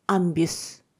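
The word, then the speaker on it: un bus: で、これ、路線バス 1 台だと、アンビュス、アンビュス。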